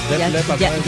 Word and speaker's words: Def Leppard: Def Leppard.